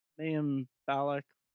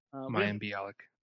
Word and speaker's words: Mayim Bialik: We have Mayim Bialik in butchered it. – Mayim Bialik.